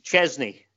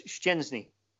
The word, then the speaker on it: Szczęsny: I mean, you know, Arsenal had a goalkeeper in England for a long time whose name for some reason got interpreted as Chesney, you know, and his name is Szczęsny. You know, there's two sounds at the first couple of syllables, and then there's an N in there, a soft N in Polish.